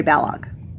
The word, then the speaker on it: Balogh: So, yes, and the new Mary Balog, I don't know if it's Balog or Balo. I apologize if I mispronounced it. It rhymes with Kellogg, so Mary Balog.